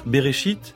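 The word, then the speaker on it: Bereshit (בראשית): Un mot en hébreu de 6 lettres, qui se prononce « Bereshit », et qu'une certaine tradition largement partagée propose de traduire par « au commencement ».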